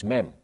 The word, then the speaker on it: Mem: That is M, but it's pronounced Mem.